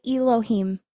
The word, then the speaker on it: Elohim: Bridget, she's on her special mission, and her personal goals is that she wants to become a creator, an Elohim. Did I say that right? – Yes.